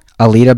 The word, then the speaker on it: Alita: Akita? Alita? – Alita Battle Angel.